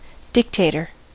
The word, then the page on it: dictator: American English